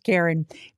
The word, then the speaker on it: Carron: Karen it's spelled different, C-A-R-R-O-N. It's a gentleman, but I think it is ironic that the name's pronounced Karen, because he is kind of a Karen.